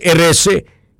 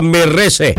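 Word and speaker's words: Erice: Klaruhin natin mag-pronounce Erese. Kasi kung minsan pag nagkamali ka, mahulog sa Merese.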